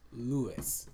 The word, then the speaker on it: Louis: It's spelled L-O-U-I-S. So it kind of like Lois, but... – Yeah, yeah. – That's worse. When I tell people my middle name and I spell it, you know, like Lois from Family Guy? No, Lewis.